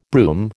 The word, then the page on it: brougham: American English